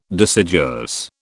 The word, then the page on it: deciduous: British English